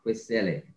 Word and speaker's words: Kwesele: Ah, Kwesele.